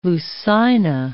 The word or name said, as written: Lucina